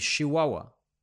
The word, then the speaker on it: Chihuahua: Como dato curioso, en Chihuahua no se pronuncia CH, se pronuncia SH, o sea que Chihuahua. Entonces se diría Chihuahua.